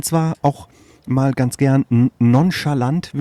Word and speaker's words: nonchalant: Das Fremdwort nonchalant, habe ich das richtig ausgesprochen? – Nonchalant heißt das doch. Ja.